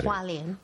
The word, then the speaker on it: Hualien: The master also wanted to address the lack of education and employment opportunities confronting Aboriginal girls in the Helene, uh, how do you pronounce that? Hualien. Hualien area.